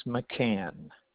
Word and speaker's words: McCann: My name is McCann.